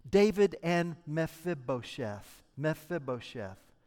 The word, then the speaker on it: Mephibosheth: Now, if you're going to walk away with something from this sermon, I want you to know how to pronounce that word. Okay? So say that with me on three. One, two, three. Mephibosheth. One more time. One, two, three. Mephibosheth.